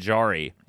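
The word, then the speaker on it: Jarry: Also, we had someone comment that it's Tristan Jari. It's not a soft J. It's a hard J.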